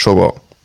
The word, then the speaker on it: Shogun: Ganz kurz, das heißt Shogun.